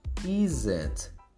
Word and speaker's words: isn't: É só não pronunciar o O. That isn't there That Ok? Sendo mais rápido, você vai pronunciar that isn't.